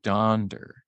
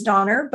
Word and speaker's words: Donder: I've heard that the correct way is actually Donder. – Oh, interesting. Okay. – Donder with like, well, something else we have to Google because I thought it was Donner, but maybe it's the correct way to say it is Donder.